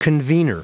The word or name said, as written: convenor